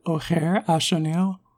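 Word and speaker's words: Ojer Axonil: Ogier Axanil? – You gotta pronounce that J more like an H. The name is more like O'Hare Ashanil. – Ah, there we go.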